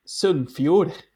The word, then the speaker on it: Sunnfjord: Sunnfjord.